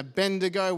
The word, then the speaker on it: Abednego: Another story I loved in the Bible growing up was Shadrach, Meshach, and Abednego, which I can finally say as an adult. It took me a long time to get my tongue around that, kids. I still said it right, Chelsea. You're not correcting me there. Good.